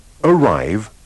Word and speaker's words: arrive: Badate bene ai tranelli della pronuncia come la E muta alla fine di change, arrive, take.